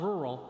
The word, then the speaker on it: rural: How do you say R-U-R-A-L? You say rural. You would not survive in Stokes County. Now, folks, I'm going to teach you some Stokes County language. That's not rural. That's rural.